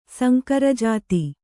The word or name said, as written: ಸಂಕರಜಾತಿ